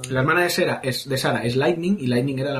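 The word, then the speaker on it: Serah: Bueno, se escribe Sara, pero es Sara.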